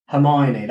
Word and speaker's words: Hermione: Hymony, Hymon, Hy, uh, Hymony. How do you ah, say it? – Neither. Yeah, the worst thing, as you're saying it now, Hermione. – That's it. Hermione.